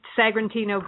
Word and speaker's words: Sagrantino: And share with our listeners about kind of just the basics of the process that you went through, starting from, the Sagrantino? Is that the, am Sagrantino. – I pronounced? Sagrantino grapes that you use in California, which there's not a whole lot of those types of grapes in California.